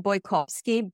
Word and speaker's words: Bojkowszky: And my name is pronounced Brigitte Bojkowszky.